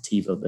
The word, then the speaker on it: Teva: Went back home and wrote, it's Teva. And, you know, we pronounce it Teva.